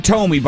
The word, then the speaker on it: Thome: In game three, Jim Thome, by the way, if you don't know, Jim Thome played for the Indians for 12 and a half seasons. – It's pronounced Thome.